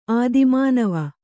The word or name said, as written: ಆದಿಮಾನವ